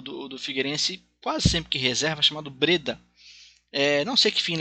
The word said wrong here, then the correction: Brida: É, é, é isso, é, eu falei errado. É, não é Breda, é Brida, isso.